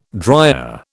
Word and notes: dryer: British English

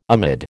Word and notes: amid: American English

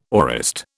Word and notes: aorist: British English

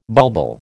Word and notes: bulbul: American English

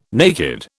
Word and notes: naked: British English

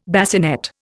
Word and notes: bassinet: Indian

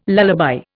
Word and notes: lullaby: Indian

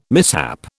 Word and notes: mishap: British English

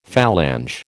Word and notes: phalange: American English

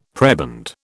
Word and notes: prebend: British English

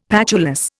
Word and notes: patulous: Indian

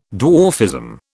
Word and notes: dwarfism: British English

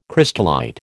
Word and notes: crystallite: American English

